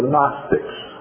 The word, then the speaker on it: Gnostic: So this is Gnostic with a almost silent G.